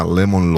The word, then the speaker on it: Lemon: Hay una ley que se llama Lemon Law. Lemon, como limón, pero con E. Se escribe L-E-M de María, O de Oscar, N de Nancy.